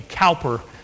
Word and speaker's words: Cowper: William Cooper wrote, "God moves in a mysterious way." We say his name, Cowper.